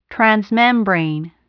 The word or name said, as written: transmembrane